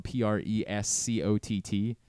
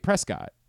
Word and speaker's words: Prescott: Uh, uh, Andrew Stecker reminded me there's a, a town in uh, Arizona that's spelled P R E S C O T T, and when you move there, you're like, oh right, Prescott. They're like, nope, Prescott. Prescott.